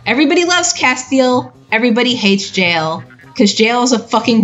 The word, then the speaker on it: Jael: Oh, wait, do I have to go about Yael and Castiel? – I believe it's pronounced Jael. – Jael? Okay.